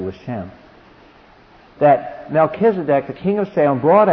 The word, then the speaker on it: Melchizedek: because verse 18 could refer to the fact Melchizedek, the Melech, the M-L-C, C-H, that kuh, that hard C-H. M-L-C-H in Hebrew is king.